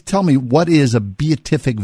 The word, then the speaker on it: Beatific: Why We Misunderstand the Beatific Vision, is that it? – It's the Beatific.